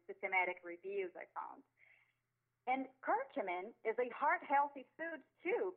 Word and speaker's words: curcumin: Curcumin, C-U-R-C-U-M as in Mary, I-N as in Nancy.